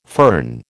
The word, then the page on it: fern: American English